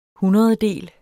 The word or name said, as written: hundrededel